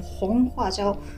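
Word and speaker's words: 红花椒: でもう一つが赤いファージャオで、ホンファージャオと言います。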